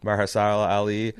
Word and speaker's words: Mahershala Ali: Luke Cage, when they go from, oh, God, is this guy's Marshala Ali? – I used to know how to pronounce his name. Sure. – Uh-huh. – Mahershala Ali.